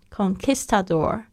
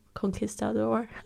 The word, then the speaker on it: conquistador: 只是那个 con conquistador，我一直很想要念成西语的那个发音 conquistador，好像那个那个英语的发音，那个应该是在 Q 上面 conquistador，conquistador conquistador。